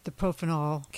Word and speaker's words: propofol: On the propofol, can never pronounce that. – Propofol, yes.